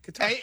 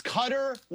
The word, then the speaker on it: Qatar: I'm a Qatar guy. – Okay. – Qatar. It's a cut. It's Qatar 100%.